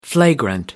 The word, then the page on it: flagrant: American English